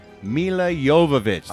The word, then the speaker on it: Jovovich: No, I always get this wrong. Mila Jovovich. That's how you say it.